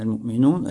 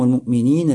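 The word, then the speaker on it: аль-му'минин (المؤمنين): Говорит он, что авлияуллах, угодники Аллаха, как уже было выяснено до этого, это верующий богобоязненный, аль-му'минун, аль-муттакун. Правильно говорить здесь «гум аль-му'минин, аль-муттакин». Хорошо, потому что «му'минин, муттакин» – это хабар для глагола «кана».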